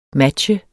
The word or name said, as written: matche